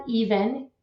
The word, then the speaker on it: Even: So we've got LinkedIn, Facebook, Instagram, or they can check out my website, Lisa Even, E-V as in Victor, E-N as in Nancy, because everyone wants to spell it wrong, .com.